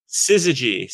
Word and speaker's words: Syzygy: How do you pronounce that? Ziggy Publishing? – Syzygy. So, Syzygy is an imprint run by Chris Ryle, who's, like, a longtime industry veteran, awesome guy.